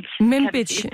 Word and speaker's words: Manbij: Og den amerikanske koalition i Syrien bekræftede i går, at den har trukket styrker ud af byen Manbij. Udtaler det korrekt? – Manbij. Ja.